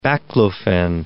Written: baclofen

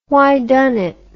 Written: whydunit

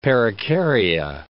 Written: perikarya

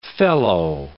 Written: felloe

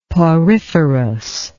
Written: poriferous

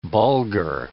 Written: bulgur

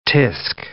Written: tsk

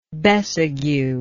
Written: besague

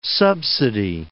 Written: subsidy

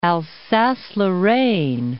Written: Alsace-Lorraine

